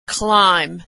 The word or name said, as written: climb